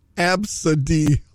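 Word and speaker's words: Abcde: It's pronounced Absadi.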